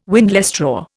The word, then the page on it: windlestraw: Indian